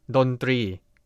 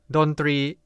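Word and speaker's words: Dontri (ดนตรี): Say it together with me, Dontri. Dontri is a Thai noun, consists of two syllables. The syllable 3 needs to be pronounced similar to the R sound in English.Another fun fact is that the word 3 actually means try or number 3 in Thai.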